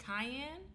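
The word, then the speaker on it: Kiyan: Is it Cayenne? – Yes. – Like the pepper? – Mm-hmm.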